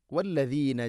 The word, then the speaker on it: والذين: വല്ലദീന ജഉ, വല്ലദീന, ഇവിടെ എല്ലാ ക്ലാസ്സിലും പറയാറുള്ളത് പോലെ തന്നെ ദാലിൻ്റെ ഉച്ചാരണം ശ്രദ്ധിക്കുക. വല്ലദീന അല്ല വല്ലദീന. വല്ലദീന ദാല് നാവിൻ്റെ അറ്റം മുൻപല്ലുകളുടെ ഇരുനരകൾക്കിടയിലൂടെ അല്പം പുറത്തോട്ട് വരണം, അതാണ് ദ.